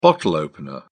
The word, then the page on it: bottle opener: British English